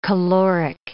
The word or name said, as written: caloric